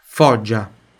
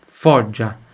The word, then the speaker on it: Foggia: Foggia, Foggia. Onde tem GI, tem som de DGI. Por exemplo, giorno, né? Buongiorno. Então, Giuseppe, Giulia. Foggia,